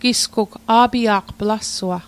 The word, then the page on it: a'pia'q: Mi'gmaq